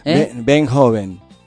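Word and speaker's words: Veenhoven: Benhoven. Y se dice así, Ruth Benhoven, se escribe así.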